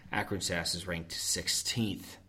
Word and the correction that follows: Arkansas: Arkansas for the rest of the people.